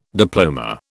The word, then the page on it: diploma: British English